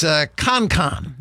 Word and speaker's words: Konkan: It is Konkan. Now it's spelled K-O-N-K-A-N.